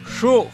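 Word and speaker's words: xu'j: Mujer en mam se pronuncia shuj. Shuj. Mujer se escribe con X, U, glotal y J.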